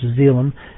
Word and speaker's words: xylem: We have the xylem, or xylem, I think it might be better pronounced, xylem, X-Y-L-E-M, which is a vascular part of the plant, which is the branch.